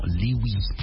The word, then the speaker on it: Louis: Il y a beaucoup à dire sur cet artiste, en tout cas Louis Armstrong, un grand petit-fils d'ancien esclave, Louis qui prononce volontiers son nom Louis à la mode Cajun, ah ouais, qui tend à l'écrire Louis avec L-O-U-I et E à la fin.